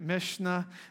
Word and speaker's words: Mishnah: But there's like this Hebrew historical things where they kept track of things called the, I'm going to mispronounce it, Mishnah. How do you say it, Kelly? Let's hear it the right way. That's it, Mishnah, right?